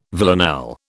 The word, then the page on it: villanelle: British English